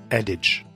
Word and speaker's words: adage: Если верить про pronunciation словаря, то это читается как adage, что очень не похоже на то, как это написано, но не впервой.